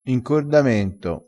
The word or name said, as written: incordamento